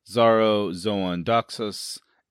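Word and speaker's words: Xaro Xhoan Daxos: Danny enters Karth to a great welcome and promises from Zaro, Zoan, Doxos, and Pratt Pree. Did I say that right? – You did.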